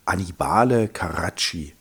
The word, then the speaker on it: Annibale Carracci: Ich sag noch den Maler, wenn ich ihn richtig ausspreche, mein Italienisch ist immer noch nicht besser geworden, Annibale Carracci.